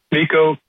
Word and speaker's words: Miko: It is M-I-K-O. It is Miko. Is it pronounced Miko, mm-hmm. or yeah. am I wrong? – Miko, yeah.